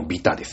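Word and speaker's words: 鐚: えー、ビタ 1 も払わねえぜのビタですよ。ね。えー、金編に悪と書いてビタ戦って読むんだけど、これはもう誰が見たって偽物。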